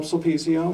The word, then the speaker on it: Sulpizio: I'm Tom Sulpizio, son of Thomas J.